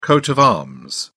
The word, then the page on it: coat of arms: British English